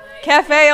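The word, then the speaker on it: café au lait: Cafe Alay? How do you say it? – Cafe Alay. – Cafe Alay. So it's spelled, you know, French.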